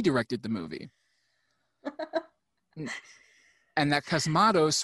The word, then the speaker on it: Cosmatos: But he was replaced by George P. Cosmatos. I'm saying that wrong. Cosmatos.